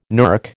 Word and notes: NERC: American English

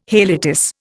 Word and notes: haliotis: Indian

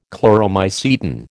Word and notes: Chloromycetin: American English

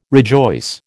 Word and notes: rejoice: American English

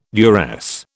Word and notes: duress: British English

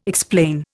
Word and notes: explain: Indian

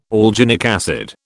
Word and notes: alginic acid: British English

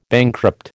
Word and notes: bankrupt: American English

